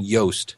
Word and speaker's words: Yoast: It's by a guy named Yoast. – Yeah. – And rhymes with toast.